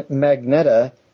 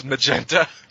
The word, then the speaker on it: Magneta: Who replaced Captain Magneta as head of the Royal Naboo Security Force? – I believe that's pronounced Magenta. – It's Magneta. Magneta.